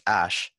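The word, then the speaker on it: Æ: The whole name is X dash AE, which is pronounced ash.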